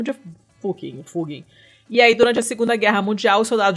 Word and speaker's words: Fugging: Fugging. Com dois G's. Como é que escreve? – F-U-G-G-I-N-G que é como é, é, é a pronúncia do nome da aldeia de acordo com o pessoal que mora lá.